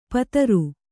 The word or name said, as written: ಪುತ್ರಿ